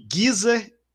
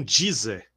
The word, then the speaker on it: Geezer: Gizer, só precisa aprender que é. Geezer e não Deezer, que é Geezer, não Deezer, Butler.